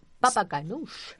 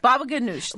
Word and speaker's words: baba ganoush: Baba ganoush. – Baba ganoush, there you go. – Which is spelled many different ways, but remember how it sounds, baba ganoush.